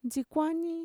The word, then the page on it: ntxikuani: Mixtec